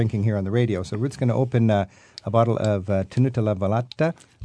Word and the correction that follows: Tenuta Le Velette: Am I pronouncing that correctly, Cecilia? – Tenuta Le Velette. – Thank you. Tenuta Le Velette.